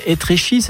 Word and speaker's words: Étréchy: Et juste un truc, parce que vous habitez donc en région parisienne, ça s'appelle Étréchy, c'est ça, on prononce comme ça? – Exactement.